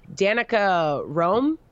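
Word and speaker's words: Danica Roem: Danica Rome.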